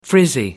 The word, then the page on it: frizzy: American English